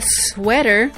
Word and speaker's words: sweater: Ahora escucha que normalmente la S es más pronunciada. Sweater.